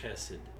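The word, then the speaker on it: hesed (חסד): with hesed. And again, that may be butchering the Hebrew language there. – I would pronounce it more like almost a K, hesed.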